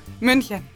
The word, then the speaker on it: München: München.